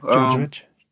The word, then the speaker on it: Djordjevic: You got Djordjevic, I think. Uh-huh. I hope I ain't saying it wrong. – Yeah, Djordjevic.